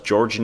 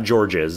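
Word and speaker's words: Georges: Niang, you don't pronounce the S. It's not George's.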